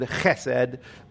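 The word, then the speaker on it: chesed (חסד): It is the word hesed. It is the Hebrew word hesed, although if you're Jewish like me, you actually pronounce it chesed, which most of you Gentiles probably cannot do, but this Jewish guy can.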